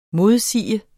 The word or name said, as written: modsige